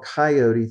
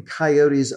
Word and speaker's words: coyote: It was called, the animal was called coyote, three syllables.